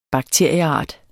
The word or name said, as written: bakterieart